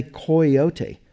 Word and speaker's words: coyote: People from, most of them sort of literary types from the East Coast or from the West Coast, tended to follow the Spanish pronunciation and say coyote.